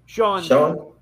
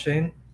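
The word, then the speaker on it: Schoen: Sean? – Yeah. Shane. I believe his name is pronounced Shane. – Is it Shane? Yeah. Not Sean? Okay.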